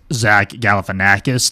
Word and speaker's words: Galifianakis: Others, Zach Galifianakis turning 57. – You get an A+ for pronouncing that correctly.